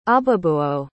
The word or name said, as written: Ababuo